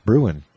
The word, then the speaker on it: Bruhn: It's spelled Brune, B-R-U-H-N, but all of his friends call him Bruin. So I guess it's pronounced Bruin, because why would his close friends all call him that?